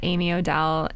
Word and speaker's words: Amy Odell: I'm at Karina, C-A-R-I-N-A, last name, H-S-I-E-H. Because you would never guess that from the way it's pronounced. – I'm at Amy Odell, A-M-Y-O-D-E-L-L.